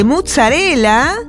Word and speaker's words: mozzarella: El ticket incluyó el consumo de una pizza chica de mozzarella.